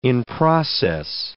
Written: in-process

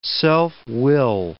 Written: self-will

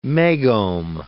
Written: megohm

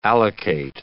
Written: allocate

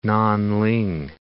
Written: Nan Ling

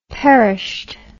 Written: perished

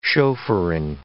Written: chauffeuring

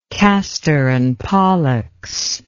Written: kastor and pollux

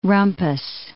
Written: rumpus

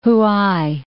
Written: Huai

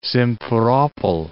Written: Simferopol

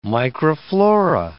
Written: microflora

